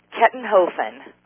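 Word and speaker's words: Kettenhofen: Kettenhoffen.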